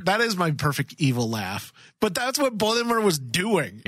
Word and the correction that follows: Boimler: No, Boimler. There's not three syllables. Two.